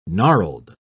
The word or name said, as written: gnarled